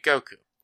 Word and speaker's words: Goku: With the Sino-Japanese system, pronouncing 5 in Japanese sounds like go, while 9 is pronounced like ku. Put them together and you get Goku.